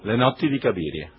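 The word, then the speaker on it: Le notti di Cabiria: ¿Cómo se dice en italiano? – Le notti di Caviria. – La notte di Caviria.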